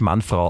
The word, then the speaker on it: Manfred: Man spricht Manfred auf Französisch tatsächlich Manfrau aus.